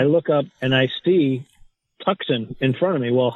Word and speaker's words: Tucson: He just, T-U-C-S-O-N, Tucson. Tucson.